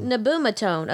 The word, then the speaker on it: nabumetone: Did I butcher it? Nabumetone. Nabumetone, okay. Methotrexate, low dose of prednisone, and Plaquenil for RA, for which I was diagnosed at age 13.